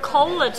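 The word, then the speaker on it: kolde: Kolle, like the word kolle today would actually be spelled differently, but it's with a U instead of an O, but you know, we're going to pronounce it kolle. The D is kolle, and the D is silent.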